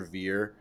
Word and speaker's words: Ver: And it's basically about Roger Ver, however you pronounce it. – Ver, yeah.